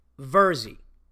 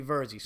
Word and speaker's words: Virzi: Because I know what it's like when people fuck up my name, because my name is pronounced Verzi, like Jersey with an E, but my name is spelt V-I-R-Z-I. So some people think it's Verzi and they fuck it up, but it's actually Verzi.